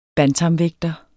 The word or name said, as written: bantamvægter